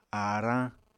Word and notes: aarañ: Apatani

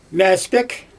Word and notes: mAspEk: Xyzyl

